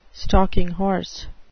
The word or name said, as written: stalking horse